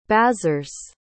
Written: bazars

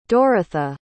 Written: Dorotha

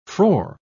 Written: frore

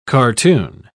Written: cartoon

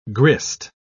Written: grist

